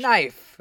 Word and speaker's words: knife: Because whenever there's a K and an N, you just, like, the K is silent in the English language. – Knife. – Right, knife. And knight.